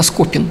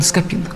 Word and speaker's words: Скопин: Вот, кстати, очень неправильно ставят ударение, иногда называют почему-то наш город не Скопин, а Скопин, может быть, это слово скопище какое-то там, а так правильно Скопин, город Скопин.